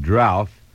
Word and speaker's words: drouth: Drought, a dry spell, D-R-O-U-G-H-T. And you can also spell the word D-R-O-U-T-H and pronounce it drouth. Either one is correct.